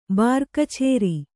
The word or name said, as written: ಬಾರಕಚೇರಿ